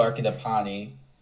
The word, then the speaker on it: Arcadipane: Samuel Arcadipani.